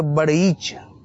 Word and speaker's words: بڑیچ: اور اس میں جو شاخ ہے وہ اس کا تلفظ جو کتاب میں مجھے ملا وہ ہے کہ وہ ڑے کے اوپر شد ہے تو بڑیچ.